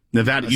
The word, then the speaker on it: Nevada: All right, Nevada. – Nevada, you said it right.